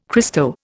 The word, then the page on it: Christo: Indian